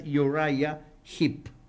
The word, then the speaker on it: Uriah Heep: La pronunciación correcta es Uriah Heep.